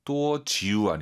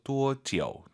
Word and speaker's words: 多久: 多久 아니고 아유 사이에 오 넣어서 多久.